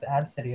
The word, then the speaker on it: ABCDE: See, it's pronounced Absidy, and it's like, why did you do that?